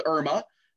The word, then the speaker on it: ERMA: It's called Irma. Mm-hmm. E-R-M-A is how that's spelled.